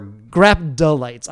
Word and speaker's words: graptolites: What's right? Is it Graploidites or... – Graptolites. I'm sorry. – Graptolites. – Yeah, Graptolites. – It's Graptolites? Okay. Yeah.